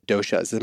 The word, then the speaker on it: doshas: So in just watching a person eat, let's actually talk a little bit about the doshas. Am I saying that right? – You are saying that right.